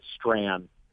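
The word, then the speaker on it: Strachan: But it is a Gaelic pronunciation that, frankly, kind of wussifies the strong Scottish element, such that I end up with Strand, but such is my fate.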